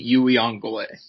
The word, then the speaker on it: Uiagalelei: So I think basically after maybe the top two guys, obviously, you got Spencer Rattler and DJ, how do you say his last name again? Is it DJ? – It's DJ Uiagalelei. – Uiagalelei.